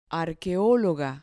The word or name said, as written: arqueologa